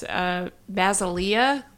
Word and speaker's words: Basilea: Well, in another bit of news, Basilea, the Spanish pronunciation of Basel, is a collaboration between artists.